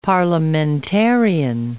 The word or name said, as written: parliamentarian